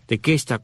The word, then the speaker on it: Tequesta: Otros indios de esta área son los llamados tequesta o tecuesta, de acuerdo como lo mencionas, lo pronuncias al inglés o el español, pero es tequesta, tequesta con Q, T-E-Q-U-E-S-T-A,